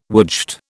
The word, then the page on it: woodshed: British English